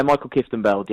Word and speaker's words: Mikkel Kieftenbeld: Mikkel Kieftenbeld, is it? I've got that wrong. – Yes. – Did I get that right? – Yeah, Mikkel Kieftenbeld, yeah. It's close enough.